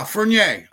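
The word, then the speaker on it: Furnier: Yeah, Fernier. Fernier. He's a hockey player. You don't pronounce the second R.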